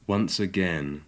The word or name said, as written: once again